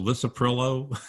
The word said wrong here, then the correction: lisinopril: Yeah, lisinopril. Yeah, lisinopril. Exactly. If